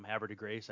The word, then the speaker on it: Havre de Grace: Havre de Grace, I would guess, but I could be completely wrong. Yeah, you got it.